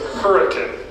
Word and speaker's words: Hurricane: And we have a city in southern Utah spelled hurricane, but we pronounce it hurricane.